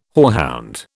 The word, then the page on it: hoarhound: British English